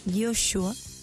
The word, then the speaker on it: Yeshua: Recordar que Jesús, que se pronuncia Yeshua, ¿ya?, tiene el, como de alguna manera es la derivación de un gran líder israelita que es Josué.